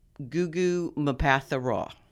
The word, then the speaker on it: Mbatha-Raw: Taika Waititi and Gugu Mbatha-Raw.